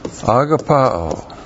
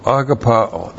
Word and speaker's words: agapao: Agapao. A-G-A-P-A long O. Agapao. That's the verb.